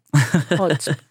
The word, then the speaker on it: Haute: Hot. H-A-U-T-E. Are you H-A... H-A-U-T. E-T. No. – Is it? – Hot. – Hot. Yes. – Hot. – You got the pronunciation right.